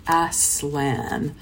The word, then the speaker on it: Aslan: And then he talks about how he pronounces it. He pronounces it as Aslan.